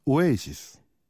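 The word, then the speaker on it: Oasis: 영국 발음으로는 오에시스.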